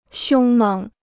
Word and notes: 凶猛: xiōng měng

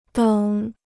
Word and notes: 等: děng

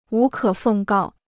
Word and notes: 无可奉告: wú kě fèng gào

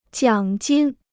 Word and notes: 奖金: jiǎng jīn

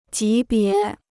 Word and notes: 级别: jí bié

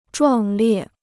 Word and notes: 壮烈: zhuàng liè

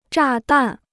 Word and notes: 炸弹: zhà dàn

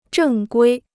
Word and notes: 正规: zhèng guī

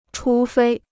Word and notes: 除非: chú fēi

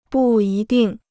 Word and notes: 不一定: bù yī dìng

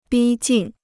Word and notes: 逼近: bī jìn

